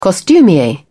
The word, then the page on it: costumier: British English